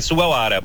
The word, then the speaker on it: Sugawara: Acontece em alguns nomes o W tem som de V. Aí tem um jogador que tem o nome Sugawara, a pronúncia é Sugawara, mas é com W.